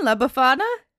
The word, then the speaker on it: La Befana: Am I saying it right then? La Befana? – Yeah, that sounds right.